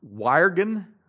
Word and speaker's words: wyrgan: The ancestor of our English word worry is the old English word, and I'm not even sure how to pronounce this, W-Y-R-G-A-N, Wyrgan, Wyrgan, which meant to strangle. The middle English word for worry was worrying, W-O-R-I-E-N.